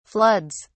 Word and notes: floods: British English

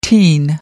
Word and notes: teen: American English

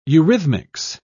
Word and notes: eurythmics: British English

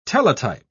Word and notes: teletype: British English

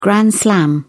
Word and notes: grand slam: British English

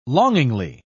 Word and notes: longingly: British English

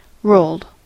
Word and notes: rolled: American English

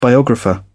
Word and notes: biographer: British English

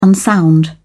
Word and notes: unsound: British English

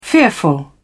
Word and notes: fearful: British English

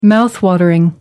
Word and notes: mouth-watering: British English

